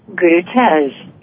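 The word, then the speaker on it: Gurutej: It's G-U-R-U-T-E-J. – Uh-huh. – And, you know, it's almost like I want to say Guru Tez. – That's right. And then you're very close to that.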